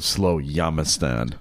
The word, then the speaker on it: Slowjamistan: So how does someone become a citizen of Slowjamistan? I just pronounced it correctly there. Did you hear that? – I love it. Yeah, yes, it is.